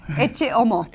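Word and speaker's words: Ecce Homo: Uh, how do you say Echo Homo? – Eche Homo. – Eche Homo. Okay.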